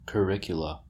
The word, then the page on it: curricula: American English